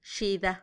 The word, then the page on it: xida: Macuiltianguis